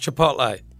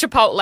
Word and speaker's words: Chipotle: I've learned that Chipotle was is pronounced Chipotle. Was that it? No. – Yeah, it's Chipotle. – Chipotle, yeah. – It's not Chipolata, it's Chipotle, and they're two different things.